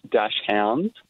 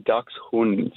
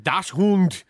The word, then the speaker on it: Dachshund: I had a friend tell me about this one, actually, but people pronounce it Dash Hounds, and it's actually pronounced Ducks Hund. – Dachshund.